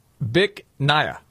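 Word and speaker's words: Bich Nga: Which he was going to have a little fun with, because the name of the restaurant, you pronounce it Bic Naya. – Bic Naya? – Yes, but it's spelled B-I-C-H, one word. Yeah. Second word, N-G-A.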